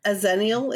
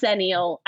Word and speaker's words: xennial: A Zennial? Is that what you said? – Yes. I think that's how you say it. I don't think it's Xennial. I think it's Zennial. – Yeah, I think you're right. – Because it's spelled X-E-N-N-I-A-L.